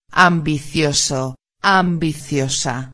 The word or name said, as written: ambicioso-a